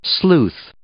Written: sleuth

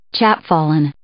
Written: chapfallen

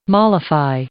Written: mollify

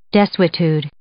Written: desuetude

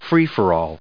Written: free for all

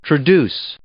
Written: traduce

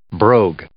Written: brogue

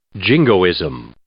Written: jingoism

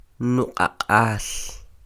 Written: nuq'aq'al

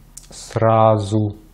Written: сразу

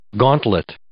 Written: gauntlet

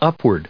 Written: upward

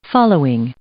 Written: following

